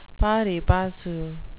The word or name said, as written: pari passu